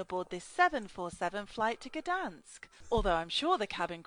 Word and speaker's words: Gdansk: That's how you pronounce the word Gdansk. The first word you pronounce as good.